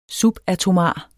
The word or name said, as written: subatomar